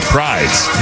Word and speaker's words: Prydz: Is it Eric Prydz? – Yeah. It's Prydz, yeah. – P-R-Y-D-S.